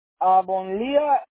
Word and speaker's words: Avonlea: Oye, así se dice esta calle en español, porque no la puedo pronunciar ni en inglés, porque Avonlea, pero creo que es una palabra de español, ¿no? – No, es Avonlea. No es palabra en español.